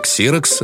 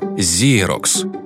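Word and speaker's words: Xerox: Слово «ксерокс» нарицательное, а произошло оно от названия первого производителя копировальных аппаратов, который правильно произносится «зерокс». В СССР продукция этой компании попала в 1968 году, когда ее представили на выставке первых копировальных аппаратов, а в 1974 году открылось ее представительство в Москве. И с самого начала название марки начали произносить неправильно, то есть «ксерокс». Сработала обычная транслитерация по первой букве X и принцип «как написано, так и читается».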